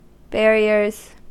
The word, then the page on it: barriers: American English